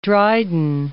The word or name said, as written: Dryden